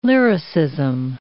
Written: lyricism

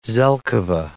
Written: zelkova

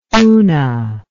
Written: oona

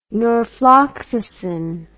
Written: norfloxacin

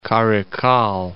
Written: Karaikal

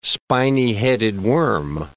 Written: spiny-headed worm

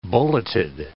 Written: bulleted

